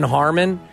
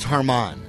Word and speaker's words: Harman: Yeah, and Brian Harman, two guys are tied one shot back, minus six. – That's Harmon. – Harmon, is that how you pronounce it? – I'm kidding. – Harman, I don't know.